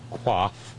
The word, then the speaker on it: coif: Oh, she has lovely hair, but the term, the coif, and understand that legal French is pronounced badly on purpose, it's coif, but the Order of the Coif.